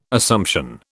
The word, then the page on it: Assumption: British English